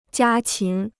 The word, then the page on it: 家禽: jiā qín